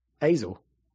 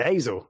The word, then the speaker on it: Azel: I just say Azel. Like, kind of like gazelle.